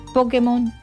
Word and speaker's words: Pokémon: Es Pokémon. Pokémon. O Pokémon. Bueno, lo cierto es que tiene acento en realidad.